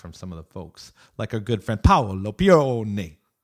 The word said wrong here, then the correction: Paolo Pione: I didn't say that right. Sorry, Paolo. I'm an American. – He won't care because you're saying his name. – Paolo Pione. There we go.